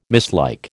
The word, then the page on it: mislike: American English